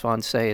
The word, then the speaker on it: Wannsee: I've heard it pronounced different ways, but I believe it's Wannsee is how it's pronounced. If I'm incorrect, let me know.